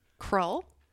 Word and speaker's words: Krull: It is Krull, a movie selection from 1983.